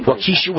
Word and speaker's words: Waukesha: Let's talk about the parade in, I think you pronounce it Waukesha. Am I pronouncing it right? Waukesha? – Yeah. – Waukesha, Wisconsin Christmas Parade.